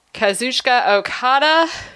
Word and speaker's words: Kazuchika Okada: And last but not least, we have the IWGP Heavyweight Championship match, Jay White versus Kazuchika Okada.